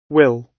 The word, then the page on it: will: British English